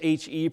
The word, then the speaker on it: He: Today we'll be considering the fifth stanza, eight verses, under the Hebrew letter title He, that is H-E, pronounced He, and under this subtitle for our message today.